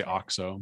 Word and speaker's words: OXO: I think mine is OXO. – Okay. – Is that what, that's how you say it, right? – You or I always say OXO, but. – OXO.